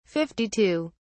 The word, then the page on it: 52: British English